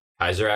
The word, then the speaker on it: Caesar: Kaiser actually, as it's supposed to be pronounced.